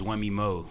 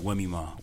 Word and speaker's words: Wemimo: So that's like my full government name, but like my first name is Wemmy Mo, like Wemmy Mo, Wemmy Mo. You can pronounce it either way.